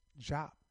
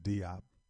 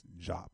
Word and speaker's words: Diop: Jop, some pronounce it Diop. I heard to say this Jop, so whatever.